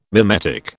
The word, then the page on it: mimetic: British English